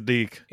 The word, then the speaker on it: DIC: How do you pronounce that? It's Deke, right? Or is it? – Yes, it is Deke.